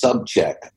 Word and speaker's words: Sobczak: His last name is pronounced Subcheck, but it's actually spelled S O B C Z A K.